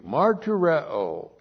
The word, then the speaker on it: martureo: Martureo, M-A-R-T-U-R-E. Long O, martureo.